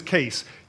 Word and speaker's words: Case: My last name is Case.